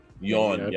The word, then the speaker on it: Yeun: Y-E-U-N. I don't pronounce that. That's you. I pronounce it right. – Yeun. – Yeah, Stephen Yeun.